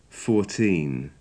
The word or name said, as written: fourteen